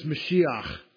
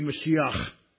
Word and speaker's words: Mashiach: In Hebrew, it's Mashiach because the H at the end is a chet. This letter here, let me show you. This letter here in Hebrew is a chet, and it would be pronounced ch instead of huh, because there's two H's in Hebrew.